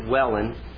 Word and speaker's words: Welwyn: It looks like Welwyn, W-E-L-W-Y-N, but I understand it's pronounced Welland.